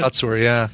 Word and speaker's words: Kotsur: Best supporting actor, is it Tony Kutsur? Kotsur? Is that how you say it? – Kotsur, yeah.